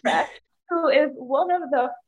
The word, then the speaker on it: Resch: It's Resch. So it's like Resch. Fresh. You just take the F off.